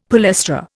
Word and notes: palestra: Indian

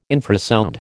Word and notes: infrasound: American English

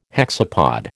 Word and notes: hexapod: American English

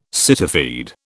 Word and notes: cityfied: British English

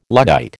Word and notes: Luddite: American English

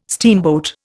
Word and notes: steamboat: Indian

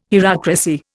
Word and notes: hierocracy: Indian

